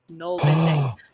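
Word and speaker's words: nålbinding: Okay, yeah, gnarlbinding. – Say it gnarlbind. I was told off by one of my subscribers for saying gnarlbind, but it's pronounced the little... It's got a little circle above the A.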